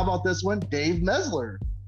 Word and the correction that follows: Meltzer: You keep saying his name wrong. – I've he's never, not that important enough. He's not that relevant. He's not that relevant enough. – He's not that guy. It's Meltzer. And at first I thought you were just saying it like that on purpose to mock him, but no, you actually do that.